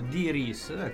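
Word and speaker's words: Dee Rees: Infatti, allora, Paria è l'altro film che nel 2011 ha presentato Diris, questa è la pronuncia esatta,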